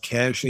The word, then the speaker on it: Cache: I'm with the Cash Agency, which is it's spelled C-A-C-H-E. So it looks like cachet, but it's pronounced Cash Agency.